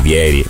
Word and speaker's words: Vieri: Allora, Benve, che tra poco andiamo a sentire, anzi direi a Tonellissimo di attivare il collegamento, dice: si dice Vieri, e per me Benve, che parla spesso con i brasiliani, c'ha ragione, però chissà.